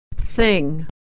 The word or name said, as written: thing